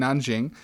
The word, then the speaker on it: Nanjing: Shenzhen and Nanjing.